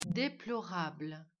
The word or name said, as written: déjà-vu